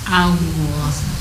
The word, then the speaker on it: Allah: Allah. – Yes. Ya, jadi A itu tipis, lohnya itu yang tebal karena huruf lamnya yang ditebalkan. Fakhimil lama min ismillah ya. Jadi cara bacanya Allah.